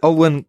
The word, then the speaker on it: Olwen: Alan Ford, Bricktop himself, Justin McDonald, Olwen Catherine Kelly. – Yeah. – That's how you pronounce her name, right? Olwen? – Yeah.